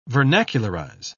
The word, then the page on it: vernacularize: British English